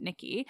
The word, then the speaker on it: Nike: So I didn't, like, I know that Nikki is based off Nike because in Greece they basically pronounce it Nikki.